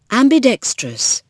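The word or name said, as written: ambidextrous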